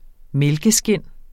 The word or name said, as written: mælkeskind